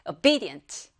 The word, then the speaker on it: obedient: 여러분들 영어를 잘하려면요, 강세만 딱잘 맞춰도 발음이 슉 됩니다. 한번 따라해볼까요? obedient.